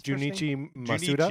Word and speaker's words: Junichi Masuda: His Junichi Masuda.